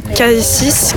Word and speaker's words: Kasis: Et du coup, mon Insta, c'est DJ Kassis, K-A-S-I-S -MTP sur Insta.